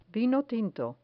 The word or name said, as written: vino tinto